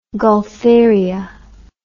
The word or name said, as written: gaultheria